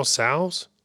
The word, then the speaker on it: Tso: General Tso's? – General Tso.